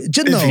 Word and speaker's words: Genova: Yeah, it does take place in the general vicinity of Genoa, the city in Italy. – Genova. Yeah. It's Genova. Yeah, I think it's Genova. – Genova. – Yeah, you're not pronouncing it quite right.